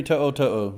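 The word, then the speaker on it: To'oto'o: One of them, of course, how do you say his name? To'o To'o? – Henry To'o To'o, yes.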